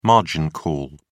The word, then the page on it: margin call: British English